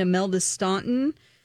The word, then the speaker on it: Imelda Staunton: I felt completely different about the woman playing the Queen, Imelda Staunton.